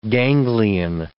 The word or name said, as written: ganglion